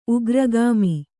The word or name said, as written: ಉಗ್ರಗಾಮಿ